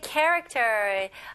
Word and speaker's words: character: 자, 두 번째는 이제 character. Okay, character는 인격 혹은 기질입니다. 구체적으로 설명해 드릴게요. 일단은 발음 연습부터 해 볼게요. 자, character 여기 CH, K 발음이죠? Character. Character. Character 강세가 어디 들어가요? 네, 첫 번째 음절이죠.